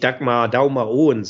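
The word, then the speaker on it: Dagmar Aaen: Und das spricht sich Dagmar Daumer Owen, sagen die Dänen dazu.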